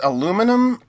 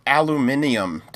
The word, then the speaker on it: aluminium: it's aluminum or aluminium, depending on where you live.